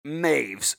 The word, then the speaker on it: Meiwes: Now, I will say, you're going to see his name is spelled Meiwes. It looks like it's Mewies. He's German. It is pronounced Armin Maves.